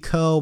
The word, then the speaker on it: Koh: Coe. My last name is spelled K-O-H.